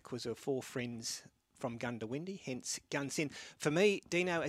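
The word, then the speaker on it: Goondiwindi: The Gundawindi is pronounced Gundawindi.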